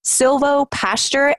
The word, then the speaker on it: silvopasture: I'm probably mispronouncing it too, but silvopasture and co-grazing. So I get the co-grazing, but what exactly does silvopasture mean? – So silvopasture, and you did pronounce it correctly, by the way, is it's a fairly new concept.